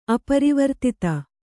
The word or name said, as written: ಅಪರಿವರ್ತಿತ